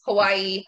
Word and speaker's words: Hawaii: So it's Hawaii, technically, because the W is pronounced with a V if you're actually speaking Hawaiian.